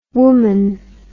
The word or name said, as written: womon